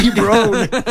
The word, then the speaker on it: LeBron: LeBron.